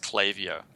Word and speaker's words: Klaviyo: By the way, I found out today that Klaviyo is actually pronounced Klaviyo.